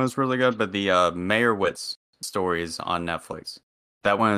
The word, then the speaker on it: Meyerowitz: It's very hard to pronounce. It's Mayer, and then O, and then Witz. – Meyerowitz. Yeah. Like Meyerowitz.